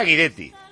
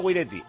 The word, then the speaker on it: Guidetti: Y que Guidetti se pronuncia Guidetti. Que decíamos Guidetti y desde Vigo nos decían, no, no, es Guidetti, Guidetti, que le llama a todo el mundo Guidetti.